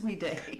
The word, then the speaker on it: Weed: Where the family moved to after the sale of the home to Henry Weed. – Right. – So, it's very possible it was, down the street. – Now, is it pronounced Weed or Weede? – It's Weede. Like Weede.